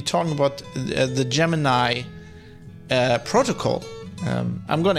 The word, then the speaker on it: Gemini: Although I think the correct pronunciation pronunciation is Gemini.